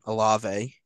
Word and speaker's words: Olave: But you still got Kamara and Olave. Hey, I said his name right that time.